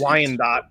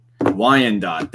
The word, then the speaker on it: Wyandotte: Wyandotte. – Wyandotte. There we go. Thank you. You're better edumacated than I am. But yeah, so Wyandotte, Michigan, absolutely free.